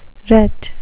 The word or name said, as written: ret